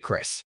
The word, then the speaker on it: Chris: My name is Chris, he, him, his. I spell my name Chris, and I pronounce it Chris.